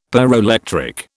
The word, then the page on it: pyroelectric: British English